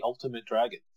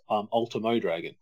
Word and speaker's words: Ultimo Dragon: It's like, is it Ultimate Dragon or Ultimo Dragon? So you can watch those old Nitros and actually hear Shivani say, here comes the Ultimate Dragon. Mm-hmm. And it's actually pronounced Ultimo Dragon.